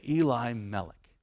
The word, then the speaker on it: Elimelech: And a man who I'm calling Eli Melek, because if you were to say, my God is my king, you would say Eli Melek. Now we would read that and go, that's Elimelech. That's how you'd pronounce it.